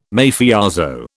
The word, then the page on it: Mafioso: British English